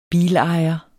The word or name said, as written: bilejer